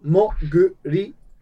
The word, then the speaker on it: Moguri: Can you write it out like, yeah, M-O-M-O-G-U-R-I. Muguri. – Muguri. – Muguri. – M-O-G-U-R-I. We're not stopping to fight. – I am me? – Moguri. – Yeah, it's more. Okay, so I'm saying it right. Okay. Moguri mod. – Like, Moguri.